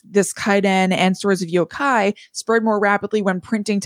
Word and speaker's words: yokai: And so some of those characters of those stories have as well kind of like been passed along, known as yokai, which I know you're going to talk about. And that was is that how you pronounce it? – Yokai. – Yokai, okay.